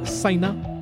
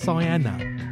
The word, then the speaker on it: Saïna: And we've got two tracks from a great singer-songwriter, Saïna, or I think it's pronounced Saïna.